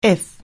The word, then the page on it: if: anglais